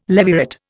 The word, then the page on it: levirate: Indian